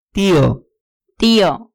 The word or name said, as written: tio